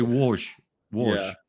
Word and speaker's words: Łódź: The place I went to was Łódź, which is spelt L-O-D-Z. So it reads Lodz. – But yeah, the L with the line through it, right? – Yeah, so it's actually Łódź. Łódź.